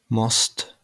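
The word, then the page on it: most: Slovak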